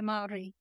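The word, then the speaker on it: Māori: The Maori, yeah.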